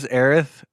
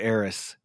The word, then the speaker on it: Aerith: I know that the official pronunciation is Aerith, but I still want to say Aeris. It's to me, it sounds better, and it's hard for me to pronounce it correctly. I have to like double. – Just remember, that. – It's supposed to sound like Earth. Like that's why her name is Aerith.